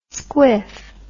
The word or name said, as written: squiff